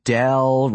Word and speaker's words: Dell: Aunt Dell rhymes with hell, ring a bell? – She was right.